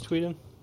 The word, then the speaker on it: Tweten: Or yeah, just hit me up on Facebook, Chris Tweeden.